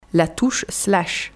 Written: La touche slash

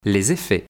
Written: Les effets

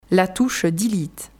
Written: La touche delete

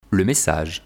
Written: le message

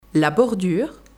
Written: la bordure

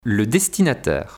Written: le destinataire

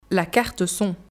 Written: La carte son

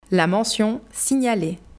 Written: La mention « signaler »

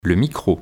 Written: Le micro

